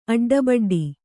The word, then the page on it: ಅಡ್ಡಬಡ್ಡಿ: kannada